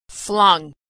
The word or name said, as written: flung